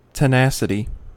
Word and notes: tenacity: American English

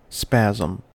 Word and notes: spasm: American English

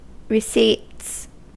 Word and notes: receipts: American English

